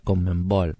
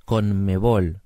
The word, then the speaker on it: Conmebol: Con, ¿Te acordás que no, él no podía pronunciar Comenbol No, decía... Ah, bueno, a mí me quedó así, Comenbol Y es conmebol pero él decía la Conmebol,